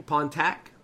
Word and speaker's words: Pontac: Uh, is that how you say his name? Pontac? Pontac? – Mm-hmm.